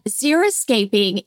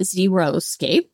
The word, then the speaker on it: xeriscaping: And some people mispronounce it and they call it xeriscape. And it's not zero because there's plants in there.